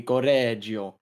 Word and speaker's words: Correggio: I think you have to roll the R. – Correggio. – Correggio. Okay, so I'm kind of unrelated, but in Italian, when there's two of the same syllable, there's kind of a stop in between. So it would be Correggio.